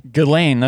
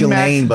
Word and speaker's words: Ghislaine: How do you pronounce her name? – Ghislaine. That's according to Vanity Fair. – Is it Ghislaine? – It's Ghislaine, but we call her Ghislaine because it's funny.